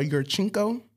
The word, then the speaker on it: Yurchenko: A uh, Yurchenko.